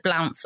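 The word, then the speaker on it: Blount: She comes from a very old family, the Blounts or the Blunts, and they pronounce it in different ways.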